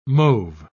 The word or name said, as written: mauve